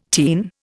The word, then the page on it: teen: Indian